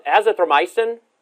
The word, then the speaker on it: Azithromycin: Azithromycin? – Yeah.